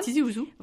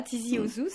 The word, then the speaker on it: Tizi Ouzou: Alors, je ne sais pas si je prononce bien. – Non, très bien, c'est Tizi Ouzou. – Voilà, Tizi Ouzou, c'est joli, c'est chantant comme nom aussi.